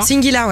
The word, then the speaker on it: Singular: Et là, dans une minute, il y aura Singular. On prononce comme ça? – Singular, ouais.